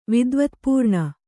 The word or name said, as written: ವಿದ್ವತ್ಪೂರ್ಣ